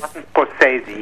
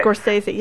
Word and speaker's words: Scorsese: Including yourself and Martin Scorsese, is that how you pronounce his name? – Scorsese. – I was Scorsese. Yes. – Scorsese. Yes. I was interested in his story because he came to.